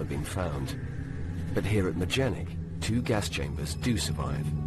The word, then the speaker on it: Majdanek: On the edge of the city of Lublin, you can still find large parts of Majenik, the largest concentration camp outside of the German Reich. – The announcer repeatedly mispronounces the name of the camp, Majdanek, as...